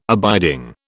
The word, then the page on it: abiding: British English